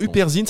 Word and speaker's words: Huperzine: Alors, uperzine, ça s'écrit H-U-P-E-R-Z-I-N-E.